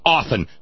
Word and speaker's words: often: Often. God damn it. The word is often, not often. Often. O-F-T-E-N. The T is silent. Often. It is oft if you don't have the E-N.